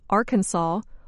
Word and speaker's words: Arkansas: The pronunciation Arkansas follows the French custom of not pronouncing the final S.